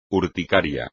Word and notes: urticaria: espagnol

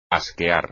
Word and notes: asquear: espagnol

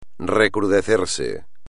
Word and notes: recrudecerse: espagnol

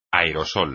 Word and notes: aerosol: espagnol

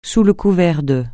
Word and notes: sous le couvert de: francais

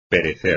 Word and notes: perecer: espagnol

